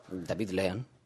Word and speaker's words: David Lean: David Lean.